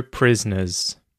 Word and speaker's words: prisoner: I would say, I can say each syllable clearly, prisoner, but when I speak normally, I actually reduce this to just prisoner. Prisoner. A prisoner, a prisoner. Prisoner. Prisoner. So the O there just sort of disappears, and that syllable disappears.